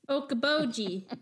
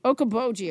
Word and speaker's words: Okoboji: I can't remember how to pronounce it. – Okoboji. – Okabo- Okoboji. – Okoboji, of course.